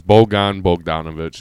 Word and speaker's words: Bojan Bogdanovic: So the Pistons acquire Utah's Bojan Bogdanovic. Did I say that right? – It's Bojan Bogdanovic.